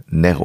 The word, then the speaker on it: Nero: Man betont dann im Lateinischen bzw. Griechischen, äh, Griechischen dann die erste Silbe und dann heißt es äh, Nero und nicht Nero.